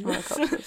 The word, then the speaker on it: monachopsis: So, remember there is, it is spelled M-O-N-A-C-H-O-P-S-I-S. There's no R in that one. – Monocopsis.